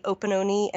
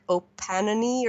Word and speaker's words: Opononi: It looks like the way to pronounce them, that's how the correct pronunciation. When I first saw it, I thought it was Omapare and like Opanani or something, but they're about an hour and a half drive from Kerikeri.